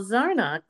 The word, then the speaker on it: Zarnock: Hi everyone, it's Studio City Now, and today my guest is the iconic Michael Zarnock.